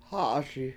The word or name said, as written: hA7aSi